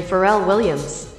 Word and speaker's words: Pharrell Williams: Daft Punk featuring Pharrell Williams.